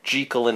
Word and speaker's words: Jekyll: I'm going to really try to stick to the original pronunciation. We've been really bad about it, but it is Jekyll and Hyde is the proper original pronunciation, and we have been bad at being consistent about it.